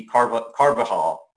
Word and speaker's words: Carvajal: I mean, let's say the Anglo version of it would be, you know, would be Carvajal.